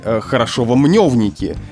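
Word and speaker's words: Хорошёво-Мнёвники: Ну, например, из-за того, что мы не всегда пишем букву «ё», возникают путаницы в таких названиях, как «Хорошовский» или «Хорошово-мневники».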